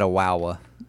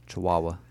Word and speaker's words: Chihuahua: You said a wawa. Chihuahua.